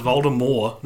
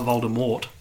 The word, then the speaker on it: Voldemort: Well, apparently, according to J.K. Rowling, it's pronounced Voldemort, not Voldemort.